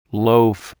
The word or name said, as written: loaf